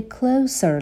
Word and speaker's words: closer: Closer. به تلفظ دقت بکنین. صدای سه رو داریم اینجا.